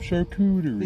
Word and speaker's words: charcuterie: There's some charcuterie. – Is that how you say it? Charcuterie? – Charcuterie.